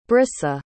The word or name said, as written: bryssa